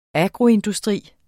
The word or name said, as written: agroindustri